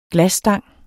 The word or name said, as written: glasstang